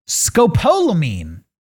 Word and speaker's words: Scopolamine: Scopolamine.